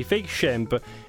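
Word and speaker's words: fake Shemp: Si pronuncia così, fake champ.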